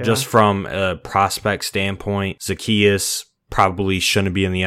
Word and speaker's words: Zaccheaus: I thought it was Zaccheaus, by the way, all week last week, but apparently it's Zaccheaus. So definitely pronounced that wrong like 100 times, but you know, let's talk about it.